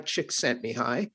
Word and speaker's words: Csikszentmihalyi: And flow is a concept that was developed by a researcher named Mihai Csikszentmihalyi. And I know it, mm-hmm. when you look at the way it's spelled, you would never think it's pronounced that way, but it is what it is.